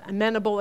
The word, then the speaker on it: amenable: That word amenable, sometimes it's pronounced amenable, it can go both ways.